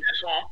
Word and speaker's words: Chisholm: I know Dylan Sandberg and Declan Chisholm? Is that how you say his name? – Chisholm.